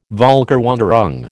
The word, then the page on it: Völkerwanderung: American English